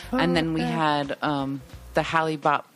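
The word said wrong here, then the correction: Hale-Bopp: Is that how that's pronounced? – Halibut. – I always thought it was Halibut. Is it Halibut? – Hail Bop.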